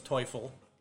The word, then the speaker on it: Teufel: They call him Teufelhunden, but German pronunciation is Teufel, devil dog.